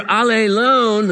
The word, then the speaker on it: allelon: Now, I was talking with a group of people on Wednesday about that, and they go, wait, can you pronounce that again? Because it sounds like you're saying all alone, which is just the opposite of what it means, one another. When you're allelon, the Greek word, the one thing you cannot be is all alone, the English phrase.